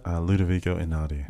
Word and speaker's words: Ludovico Einaudi: Ludovico Einaudi.